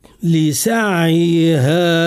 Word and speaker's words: لسعيها: العلماء قالوا رخاوة العين رخاوة متوسطة، ما هيش رخاوة كاملة ولكن فيها رخاوة. لسعيها لسع لسع، ما هيش لسعيها لسعيها. هنا إذا نطقنا بالعين هكذا لسعيها راضية، لا، العين هنا فيها اختلاس، يتسمى اختلاس، معناها سرقنا من زمن التصويت بحرف العين سرقنا جزء من صوتها، ولهذا نعطيها الرخاوة المتوسطة فنقول لسع لسعيها راضية.